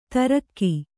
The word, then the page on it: ತರ್ಗಿ: kannada